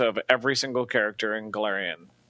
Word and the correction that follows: Golarion: Please, that's Golarion.